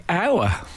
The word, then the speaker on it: hour: That's definitely a silent letter. – No. But it's never occurred to me, because it's spelt with the H, if you said per hour. But it's we don't say it, we say hour. And I've never thought about that before.